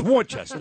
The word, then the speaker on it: Worcester: It is spelled W-O-R-C-E-S. What says Worcester about that? It's Worcester.